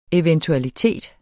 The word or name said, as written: eventualiteter